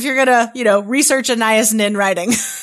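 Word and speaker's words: Anaïs Nin: Anais Nin.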